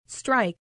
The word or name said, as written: strike